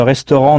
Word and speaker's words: restaurant: C'est exactement le même mot qu'en français, mais notez bien la prononciation. On ne doit entendre que deux syllabes au lieu de trois en français. Restaurant.